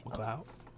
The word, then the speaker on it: McLeod: McLeod.